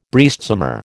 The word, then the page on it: breastsummer: American English